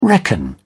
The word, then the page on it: reckon: British English